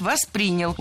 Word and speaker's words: воспринял: Было сказано «воспринял», но это неверно. Правильный вариант – «воспринял». Ударение в глаголе ставим на второй слог.